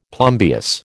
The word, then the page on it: plumbeous: American English